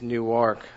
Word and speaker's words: Newark: And I've been corrected many, many times by people. It's not Newark, it's Newark.